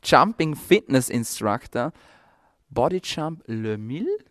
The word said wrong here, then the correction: Les Mills: habe ich das richtig ausgesprochen? Les Mills fast.